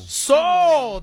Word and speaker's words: pshaw: P-H-A? – P-S-H-A-W. Do you know how... – Soul. – Soul. That's how you pronounce it.